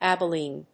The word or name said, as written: abilene